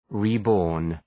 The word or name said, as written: reborn